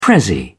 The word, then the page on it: prezzie: British English